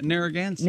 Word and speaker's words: Narragansett: Narragansett.